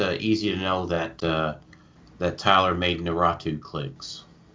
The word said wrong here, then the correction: Naruto: No, Naratu? Is that how you say it? What's the anime? – Naruto! – Yeah, see, that's how you know it's an absolute joke, because I didn't even pronounce it right.